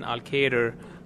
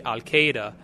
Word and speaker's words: Elkader: Well, one of the sort of the, uh, the ironic uh, things is that in, in pronouncing Elkader, uh, people generally f- say Al Qaeda. And I always like emphasize to people that R in Elkader is not a silent R. Please, please pronounce it.